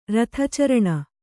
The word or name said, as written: ರಥಚರಣ